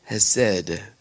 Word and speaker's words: chesed: Remember, has said. Remember, C-H-E-S-E-D. Has said, the sea is silent.